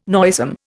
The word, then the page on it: noisome: Indian